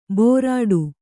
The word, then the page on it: ಭೋರಾಡು: bhōrāḍu